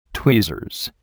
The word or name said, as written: tweezers